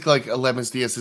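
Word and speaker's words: Aledmys Díaz: Well, I just think Aledmys Diaz is the new Jed Lowry.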